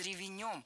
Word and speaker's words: ревенем: Творительный падеж с ревенем. Ударение в русском языке подвижное, и оно у нас передвинулось на окончание. И беглой гласной здесь быть не должно.